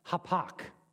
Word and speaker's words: hapak: The word in Hebrew is H-A-P-A-K. That's how you pronounce it, hapak. And I'm probably pronouncing it wrong. H-A-P-A-K, hapak.